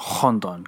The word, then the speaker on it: Rondon: But they both read on Rondon because you put the H sound on the R's in Portuguese.